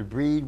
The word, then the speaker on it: Brigid: Variant forms of her name include Breed, Breda, uh, Brigid, Brigitte, pronounced either Breed or a soft G sound.